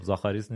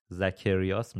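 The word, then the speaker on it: Zacharias: خب زاخاریس نیستش دیگه، اسم تلفظ دقیق اسمشو بخوام بگم زکریاس میشه.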